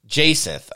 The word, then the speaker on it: jacinth: Jacksonith? How do you call it? So it's like a ruby. – Jacinth, okay.